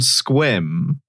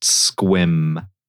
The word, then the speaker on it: Sequim: Worst part is it's not spelled squim. It's spelled like sequim, like a sequimed dress might be. And then minute one of joining the call, I was assaulted with the knowledge that it's pronounced squim.